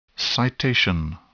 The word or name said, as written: citation